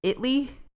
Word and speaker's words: Italy: So this town actually prefers to be pronounced Italy. – That's so Texan. – I know. I don't. – Italy.